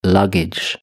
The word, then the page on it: luggage: British English